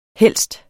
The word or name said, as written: helst